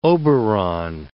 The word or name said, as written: Oberon